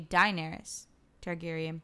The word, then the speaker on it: Daenerys Targaryen: So, Daenerys Targaryen? – Perfect. – Yes. – Daenerys Targaryen. I got Daenerys Targaryen. – You can even say Daenerys Targaryen. – Daenerys? Mm-hmm. Okay, Daenerys Targaryen.